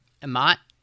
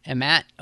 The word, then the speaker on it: Emmett: I'm having trouble figuring out how to pronounce his name. – Emmett. – See, I was saying Emmett, like Emmett, but with an A. – Emmett or Emmett, it's, yeah, either one.